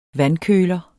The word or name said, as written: vandkøler